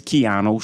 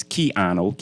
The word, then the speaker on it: Keano: On Instagram is Keanu, KEANO dot D dot S. If you have any issues pronouncing my name, because throughout my life people was calling me Keno all day, just call me Ano.